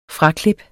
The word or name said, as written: fraklip